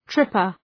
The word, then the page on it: tripper: ‘trıpər